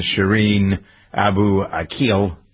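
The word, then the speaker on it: Shireen Abu Akleh: This person, Shireen Abu Akil, that's how to pronounce the name, was a reporter for Al Jazeera.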